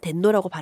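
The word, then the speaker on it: 덴노라고 (天皇): 그래서 저는 이제 자꾸 덴노는 덴노라고 발음을 해줘야 될 거. 왕이 아닌 거죠. – 네네. – 예, 예. 왕이 아니라 덴노는 덴노인 거죠.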